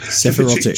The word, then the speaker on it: Sephirotic: Now, the wounds on this boy's, Andrei's boy's head was representing the part of the Sephirotic, I can never pronounce this word. – Sephirotic tree.